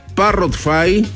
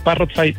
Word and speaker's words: Parrotfy: Exactamente, Parrotfy, tal cual como lo dijiste, Parrot, como el loro, con F-Y al final.